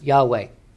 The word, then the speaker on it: Yahweh: You got Elohim, you have Adonai, and then you have the holy name of God that the Jews would not even pronounce. All right, I'm going to just do it. YHWH, okay? more, we think it's more, most likely pronounced Yahweh.